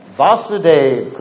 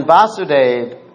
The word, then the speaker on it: Vasudeva: So when Vasudeva, because there's a difference between Vasudeva and Vasudeva. Vasudeva is the father of of Vasudev Krishna. Remember how I'm pronouncing it? Vasudev or Vasudev. Vasudev is Krishna. Vasudev is Vasudev's father. It's a little complicated, but I think you understand. There's a long A or a short A in the beginning.